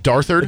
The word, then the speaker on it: Darthard: You think it's Darthard? – It's definitely Darthard.